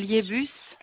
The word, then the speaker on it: Liebus: Liebus. – Ça s'écrit comment? – L-I-E-B-U-S.